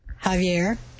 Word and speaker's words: Javier: His name, Javier, J-A-V-I-E-R, is an alternate spelling of X-A-V-I-E-R, Javier, and which we pronounce Xavier, but it's actually Javier.